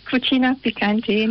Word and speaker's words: Cucina Picante: It's pronounced Cucina Picante, and it's Italian for Cucina Picante.